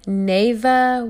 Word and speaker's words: Navaeh: So, our rising star, we have Nava Woods. Her first name is spelled N-A-V-A-E-H, and she is 9 years old.